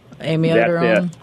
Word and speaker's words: amiodarone: Amiodarone? – Amiodarone. – Mm-hmm. Yeah. – Boy, you read the literature on that. – I have a patient, a side story, a gentleman who was put on amiodarone.